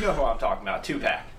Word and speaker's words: Tupac: You know, two packs, like Tupac the rapper.